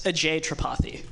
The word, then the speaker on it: Ajay Tripathi: Hey, AJ, how do you say your last name? – It's Ajay Tripathi. – Ajay. Okay, Ajay Tripathi. Yeah. All right. I just have to put the emphasis on the other syllable.